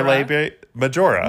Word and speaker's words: labia majora: I love how you said it in Italian. – Wait, is that not how you say it? – Minore and majore. – Wait, how do you say it in English? – Labia major labia majora. – Majora, you were right.